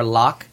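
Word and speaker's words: loch: Or lock, depending on how you want to pronounce it and what you got to rhyme it with. Because he does pronounce it both ways, depending on whether Scotland needs to be involved.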